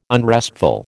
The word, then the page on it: unrestful: American English